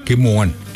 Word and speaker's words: kimowan: In Cree, how do you say rain? – I'm going to say it in a way it's happening now. Kimowan. – Kimowan.